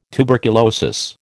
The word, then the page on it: tuberculosis: American English